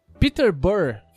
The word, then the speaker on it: Peterborough: Peterborough, falei certo? – Falou. Peterborough ah, Peter Burrow. Tem um Burrow no final.